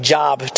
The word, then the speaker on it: Job: It looks like Job to us, J-O-B, but it's pronounced Job.